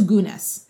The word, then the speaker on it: Gunness: Is it pronounced Gunness or Gunness? – So Belle is from Norway. Like, right. – That's her origin story. So I actually I asked my dad, because I was like, Dad, how do you say it? Because he speaks Norwegian. And he said it was Gunness.